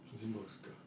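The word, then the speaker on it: Szymborska: Wisława Zimborska. Zimborska. Ah, oké, jij spreekt het mooi uit, oké.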